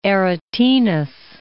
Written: Guido Aretinus

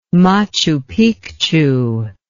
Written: machu picchu

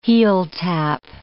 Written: heeltap

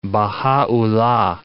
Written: Bahaullah

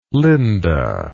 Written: linda

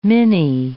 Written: Mini

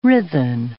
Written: writhen

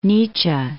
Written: Nietzsche

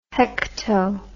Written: hekto

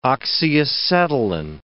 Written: oxyacetylene

